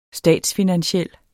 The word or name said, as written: statsfinansiel